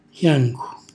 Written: xjancu

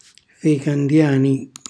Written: ficandiani

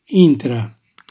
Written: intra

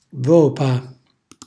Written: vopa

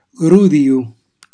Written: grudijo

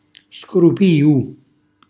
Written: scrupiu

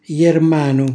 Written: jermanu

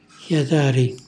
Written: xjatari

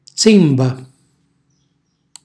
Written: zimba

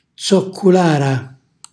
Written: zocculàra

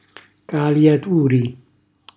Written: caliaturi

